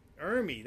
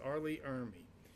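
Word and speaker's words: Ermey: It's starring Crispin Glover, Laura Ellen Haring, Jackie Burroughs, and probably the most famous asshole in the world, Ermy, that's how it's pronounced, Arlie Ermy.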